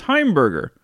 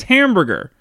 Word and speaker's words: Heimberger: Eddie Albert actually changed his birth name, which was Heimberger, because it was constantly being mispronounced as Hamburger.